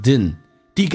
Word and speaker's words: didn't: I didn't, 在口语化当中说成 didn't,D 跟 N 组合 ,D 可以不发音所以速度很快。 I didn't mind getting caught dozing off in class.